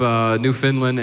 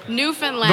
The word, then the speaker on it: Newfoundland: We've been to every province except Newfoundland and PEI. And, What? – Newfoundland. – Don't say it like that. What? How do I say it? – Newfoundland.